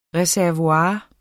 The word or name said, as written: reservoir